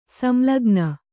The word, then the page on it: ಸಂಲಗ್ನ: samlagna